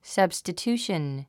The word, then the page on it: substitution: sʌ̀bstətjúːʃən; サブスタチューション